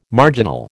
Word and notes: marginal: American English

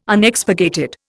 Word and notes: Unexpurgated: Indian

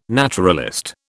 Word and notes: naturalist: British English

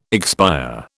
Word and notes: expire: British English

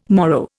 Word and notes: morrow: Indian

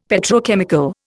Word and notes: Petrochemical: Indian

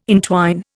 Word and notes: entwine: Indian

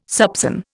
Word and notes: soupÇon: Indian

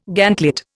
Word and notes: gantlet: Indian

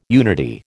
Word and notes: unity: American English

